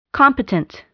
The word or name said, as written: competent